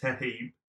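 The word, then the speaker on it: Toheeb: Am I saying his name right? – You say it Toheep, but it's T-O-H-E-E-B. – Okay. – A-A-M-O-H.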